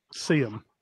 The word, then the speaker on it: Seaham: Seaham.